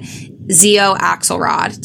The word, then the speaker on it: Xio Axelrod: So that panel was Lexi Ryan, Nikki Sloan, Nyla K, QB Tyler, Sienna Snow, and Xio Axelrod. Did I say that right? Yeah. Okay, perfect.